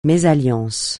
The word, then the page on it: mésalliance: francais